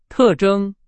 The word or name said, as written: 特征